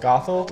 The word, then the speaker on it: Gothel: G-O-T-H-E-L. Gothel. – Gothel? – Gothel.